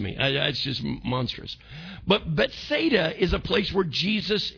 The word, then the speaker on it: Bethsaida: I said it's Bethsaida, house of. Anytime you see the word Beth in there, it really is pronounced Bet.